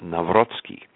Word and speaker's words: Nawrocki: My last name, Naraki, is Polish, and by the way, the pronunciation, if you want to twist your tongue, is pronounced Nawrocki, and it means simply converted.